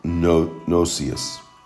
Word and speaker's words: gnosis: The Greek pronunciation is gnosis. Gnosis means knowledge or understanding. It is used several times in the New Testament, 50 times, and or 29 times, and strictly by Paul. Paul refers to gnosis, meaning mainly the knowledge of God and the things of God.